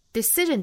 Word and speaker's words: decision: Decision 这个词我们注意它中间的这个 c 的字母要发成一个清辅音的那后面的这个 sion 本身发音应该是审，因为前面有元音因素了，那相当于要把这个 sh 浊化成 r, 那所以这个词应该读成 decision。